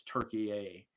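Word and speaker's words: Türkiye: The modern translation, or I guess the modern day pronunciation of Turkey now, is Turkey-A. And so Jed tends to say Turkey-A.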